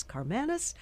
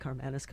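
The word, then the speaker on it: Karmanos: And Jasmine, your handler in the background, told me a year ago, this is how you pronounce Karmanis.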